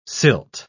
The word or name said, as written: silt